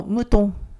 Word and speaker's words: moutons: えその次の無頓ですけれども、これ無頓は羊を意味する男性名詞の、まあ、発音が変わらないんですけれども、 S なしの無頓の複数形です。